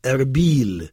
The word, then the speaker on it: Erbil: Erbil, et les Irakiens prononcent Erbil.